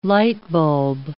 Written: lightbulb

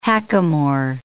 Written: hackamore

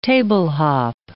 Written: table-hop

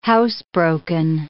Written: housebroken

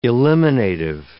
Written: eliminative

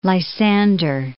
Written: Lysander

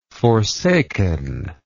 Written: forsakenness

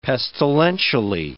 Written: pestilentially

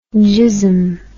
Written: gism

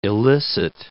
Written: elicit

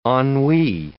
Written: ennui

